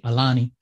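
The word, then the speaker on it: Aulani: Well, I know Disney's Aluna, am I pronouncing that? Alani.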